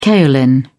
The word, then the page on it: kaolin: British English